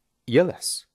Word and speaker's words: jealous: En esta parte tenemos una palabra interesante, jealous, que significa celoso o celosa. Te recomiendo pronunciarla con una I suave al principio. Jealous.